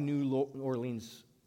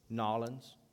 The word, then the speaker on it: New Orleans: A few weeks into the crisis, which struck the New Orleans, which, by the way, I'm pronouncing wrongly, it's Nolens.